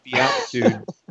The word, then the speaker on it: beatitude: In fact, leading up to the beatitude, is that right? Beatitude? – What's the correct pronunciation? Beatitude, he was eating like lemon a day or something.